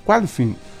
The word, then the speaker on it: Qualifying: Em seguida, a partir das 3h30, acontece o Qualified, será que falei certo? Qualifying, com descidas cronometradas dos pilotos que definem a ordem de largada da prova principal.